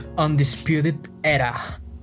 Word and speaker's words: Undisputed Era: ¿Cómo se pronuncia? Undisputed. Undisputed Era. De la Undisputed Era.